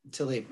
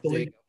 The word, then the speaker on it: Taleb: The author looks like Nassim Nicholas Taleb. – Taleb, yep. – Taleb. Yeah. So you know how to pronounce it.